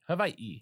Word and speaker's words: Hawaii: Is it pronounced Hawaii with like a V and an E? I know it's the E at the end. – Yeah. – But how does the W pronounce? Is it V? – Yeah.